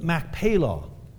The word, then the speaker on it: Machpelah: It's the cave of Machpelah. That's the real way of pronouncing it. It's the cave of Machpelah.